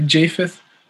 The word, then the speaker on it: Japheth: But Shem and Japheth, did I pronounce that right? – It can be pronounced that way or Japheth.